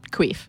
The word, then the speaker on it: Quiif: I started working for a French company called Quif, Q-U-I-I-F, but my bosses were French, so they pronounced it Queef.